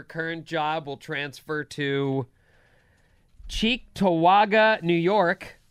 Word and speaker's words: Cheektowaga: Fargo Cheektowaga. That's the pronunciation. – It is. – You got it right.